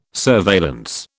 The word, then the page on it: surveillance: British English